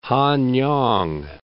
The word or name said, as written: Hanyang